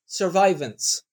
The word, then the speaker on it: survivance: survivance. That's how he pronounces it. So he has turned it into an English word rather than, you know, fancy sounding French word.